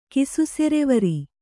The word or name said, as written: ಕಿಸುಸೆರೆವರಿ